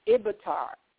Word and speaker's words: Ibitar: Ibitar. Yeah. – Avatar. – Ibitar with a B.